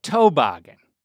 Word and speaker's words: toboggan: We called a winter hat, like a beanie, we called that a toboggan. Emphasis on the toe, right? – Yep, that's right.